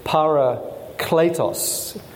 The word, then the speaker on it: parakletos: It comes from a Greek word paraclete, and the correct pronunciation, as I'm reliably informed by my by Greek scholars, is parakletos.